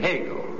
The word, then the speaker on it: Hegel: If you were asked, or we asked you to identify the German philosopher whose last name rhymes with a hard roll with a hole in the middle, you'd surely say Hegel. Name rhymes with bagel.